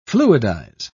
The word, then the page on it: fluidize: British English